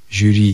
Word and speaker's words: жюри: Произносится здесь, конечно, твердо, согласно, «ш» мы можем произнести только твердо. И поэтому хочется написать после этой буквы «у». В словах «брошюра», «жюри» и «парашют» мы, тем не менее, пишем букву «ю».